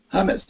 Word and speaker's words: humus: It's the Latin word for humility, and it's hummus, not the kind you eat with a cracker or a chip. It's spelled H-U-M-U-S, one M, meaning earth.